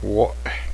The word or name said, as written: cloud